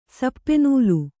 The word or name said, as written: ಸಪ್ಪೆನೂಲು